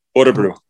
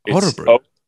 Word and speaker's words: Örebro: Örebro. – Örebro. – O-R-E-B-R-O.